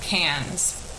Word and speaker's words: Cairns: So we found, this city, I would more call it a town, called Cairns. It's spelled like Cairns, C-A-I-R-N-S.